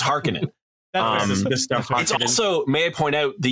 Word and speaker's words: Harkonnen: Harkonnen. – So here's the thing. – So in the 84 movie, they say Harkonnen. – Yeah. – But it should be pronounced Harkonnen.